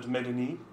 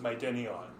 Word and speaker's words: Medenii: uh, he calls it Medini. Um, and then uh, Vernon, who's also the owner of Gin Palace, has a small share in the business, and he pronounces it the correct Latin pronunciation, which is Medenii, um, which harks back to the botanical names and stuff like that, um, which is what the, the brand's named after.